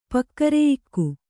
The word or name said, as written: ಪಕ್ಕರೆಯಿಕ್ಕು